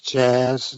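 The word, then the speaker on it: Czas: It's C-Z-A-S. So I'm very sorry for butchering your name, but welcome to the show. – It's Jazz. It's a Polish word.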